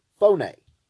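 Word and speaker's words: phoné (φωνή): Voice here is the Greek word phoné, and it's spelled like we know in the English word phone. It's P-H-O-N-E, phoné.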